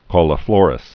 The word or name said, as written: cauliflorous